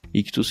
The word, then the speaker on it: Ichthus: Ictus se escreve I-C-H-T-H-U-S.